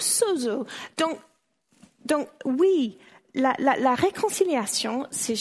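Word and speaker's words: sozo (σώζω): Sozo, dites avec moi, sozo.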